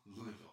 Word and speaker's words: Louisville: Who were we playing, and how do you say the name of that school? – Louisville, okay. You can say it 10 different ways, and you say Louisville? – Mm-hmm.